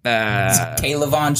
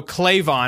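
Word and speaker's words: K'Lavon: Kalavon Chaisson, baby. – Kalavon Chaisson. I didn't know. I was going to go Clavon, and I was like, I know that is not right. – Pronounce K. Kalavon.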